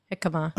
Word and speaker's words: jicama: Over at Frontera Casina, they have Order the Half and Half, which is half guacamole, half toasted pumpkin seed hummus, served in yarmulke cucumber. Did I say that right? – No, jicama.